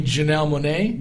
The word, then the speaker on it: Janelle Monáe: You mean Janelle Monáe? – That's it. – Okay, I was like. – Oh my gosh. – See, I embarrassed myself for the second time. Janelle Monáe.